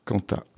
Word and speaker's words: Kanta: Donc là, on retrouvera un trio vocal féminin qui porte le nom de Kanta. – Kanta, exactement.